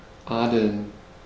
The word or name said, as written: adeln